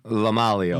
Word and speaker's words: LoMaglio: How do you say your last name, Palavio? – LoMaglio.